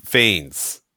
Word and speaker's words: Fiennes: Okay, I do want to mention, believe it or not, it's Rafe Fiennes. – Rafe? – He does not pronounce it Ralph. He pronounces his name Rafe and his last name Fiennes.